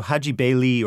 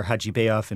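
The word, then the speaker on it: Hajibeyli: So Haji Bayli, or Haji Bayov in the Russian pronunciation, was a young composer in 1908.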